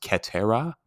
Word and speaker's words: cetera: Et meaning and, cetera in the Latin actually is pronounced cetera, which means the rest.